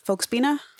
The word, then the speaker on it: Volksbühne: So will you tell us a little bit about the Volksbühne? – Absolutely. – If I'm saying it correctly? – You are saying it perfectly.